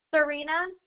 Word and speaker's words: Czarina: Serena, C-Z-A-R-I-N-A. – Can you spell that again for me? – C-Z-A-R-I-N-A.